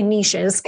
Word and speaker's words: niches: We say niches because we're Canadian. That's how we pronounce it up here.